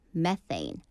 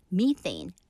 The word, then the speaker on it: methane: 메탄도요, methane, methane. 또 영국식 영어 발음은 발음으로는 methane, methane이라고 한다고 합니다.